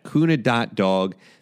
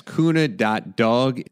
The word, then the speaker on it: cuna.dog: You can see all of that at kuna.dog. That's kuna.dog. It's efficient for you. Your dog will appreciate it as well, of course. And that's spelled C-U-N-A.D-O-G.